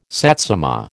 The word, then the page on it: satsuma: American English